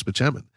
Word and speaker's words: Spaceman: And then she sees the prescription and it says Spaceman, although it's pronounced Spichemin.